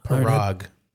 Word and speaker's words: Parag: Parag. – Parag? – Yes.